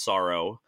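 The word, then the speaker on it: Psaro: uh, you follow, I'm assuming this is Sorrow. It's spelled P S A R O. I'm assuming it's called, it's just pronounced Sorrow.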